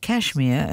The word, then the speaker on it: KSHMR: It's from Kashmir, which is like Kashmir, only without any vowels. So it's spelled K-S-H-M-R.